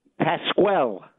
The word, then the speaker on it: Pasquel: How do I properly say your last name, Paco? – Pasquel. – Oh, Pasquel. – Pasquel.